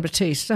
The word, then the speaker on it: Batiste: No. Yes, it is John Batiste.